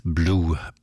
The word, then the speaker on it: Bleu: Wie die Liebe für Vicky schreibt sich auch der Nachname des nächsten Musikers, also B-L-E-U, ausgesprochen wird er aber als Blue, Benny Blue.